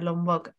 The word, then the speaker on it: Lombok: Lombok. Em, lo he dicho bien, ¿verdad? – Sí, sí, eso es.